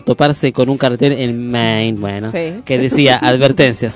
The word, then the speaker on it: Maine: Se escribe Maine, se lee Maine.